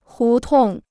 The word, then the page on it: 胡同: hú tòng